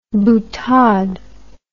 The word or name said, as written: boutade